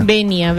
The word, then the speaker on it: venia: Venia, venia con B corta. – Con B corta, así como venia, pero el acento va en la E. – Ahí va. – No lleva tilde.